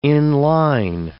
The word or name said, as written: in-line